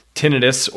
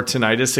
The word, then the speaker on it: tinnitus: For anyone who doesn't have tinnitus or tinnitus, it can be pronounced either way.